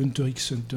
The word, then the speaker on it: Hunter x Hunter: Et on prononce le X si on vous emmerde. Voilà. – Ah ouais, ça se prononce pas d'habitude. – Bah apparemment, il y en a qui disent Hunter Hunter.